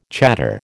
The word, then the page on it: chador: American English